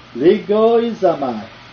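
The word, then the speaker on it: logizomai: The Greek word is legoizomai.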